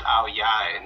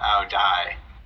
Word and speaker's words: Ao Dai: Oh, well, and Remy is telling um, Remy is telling me it's pronounced Ao Yai, not Ao Dai. It's spelled with a D uh, though, right?